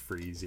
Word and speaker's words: Fries: Well, it's F-R-I-E-S, but it's pronounced Freeze, yeah.